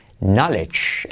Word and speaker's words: knowledge: Knowledge. La K no se pronuncia y luego una GJ. Knowledge.